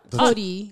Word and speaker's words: Torii: The Tory. – What? – Tori. – Tori Gate. – Yeah, Tori Gate. Yeah. – How do you spell it? – T-O-R-I-I.